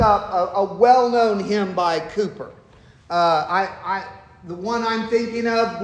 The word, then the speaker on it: Cowper: One of our great writers of our hymns, William, it's spelled Cowper, but it said Cooper.